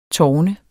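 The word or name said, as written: TÅRNET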